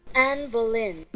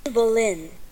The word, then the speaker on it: Boleyn: Anne Boleyn. – Mm-hmm. Really? Not Boleyn? – Boleyn. Anne Boleyn.